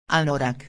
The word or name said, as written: anorak